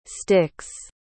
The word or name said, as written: styx